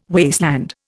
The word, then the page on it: wasteland: Indian